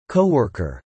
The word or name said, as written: coworker